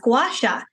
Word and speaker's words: gua sha: And I was like, oh, I never thought to look it up and actually see, was it pronounced a certain way? Lo and behold, it was gua sha.